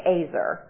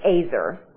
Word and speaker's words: ezer: So the word here, it's a Hebrew word, and it's spelled E-Z-E-R, and it's pronounced Azer. I always would say Ezer, but it's Azer.